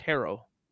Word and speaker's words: Herro: There's a lot we could do with that, but on the Heat side of things, Tyler Herro, it's pronounced Harrow, like your hair.